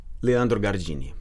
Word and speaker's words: Leandro Gardini: Say your name for us the way you would say it. – Leandro Gardini.